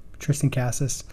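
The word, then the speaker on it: Cassius: Tristan, is it Cassis? Is that how you say his name? – Yeah. – Tristan Cassis.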